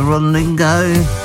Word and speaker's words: Rodrigo: Olivia Rodrigo. That's how you pronounce her second name.